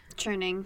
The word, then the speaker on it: churning: And, you know, she said that once when she was back on the farm down in Virginia, she was turning butter. Is that how you say it? Turning? Churning? – Churning. – Churning. She was turning butter. – Churning with a CH.